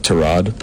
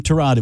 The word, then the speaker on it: Tyrod: It's correctly pronounced Tyrod, but he doesn't correct people. No, he's awesome guy. – Oh, pardon me then. Tyrod it will be.